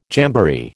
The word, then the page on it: jamboree: American English